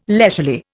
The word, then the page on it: leisurely: Indian